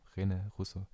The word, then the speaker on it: René Russo: Von 2019, auch mit Jake Gyllenhaal und auch mit Rene Rousseau. Rousseau. René Russo.